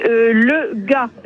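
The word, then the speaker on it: Le Gast: Oui, c'est, Le Gas, G-A-S-T, je ne sais pas comment ça se prononce. – Si, ça se prononce comme ça, effectivement.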